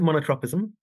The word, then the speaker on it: monotropism: So I pronounce it monotropism, but I'm endlessly fascinated by all the different ways that people find to pronounce it. Yeah, there's never been great consistency about that.